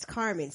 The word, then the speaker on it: Carmen: All right, my name is Carmen, C-A-R-M-E-N.